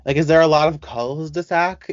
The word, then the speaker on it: cul-de-sac: And by the way, the S silent in French. So in French, it's pronounced cul-de-sac.